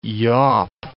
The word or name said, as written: yawp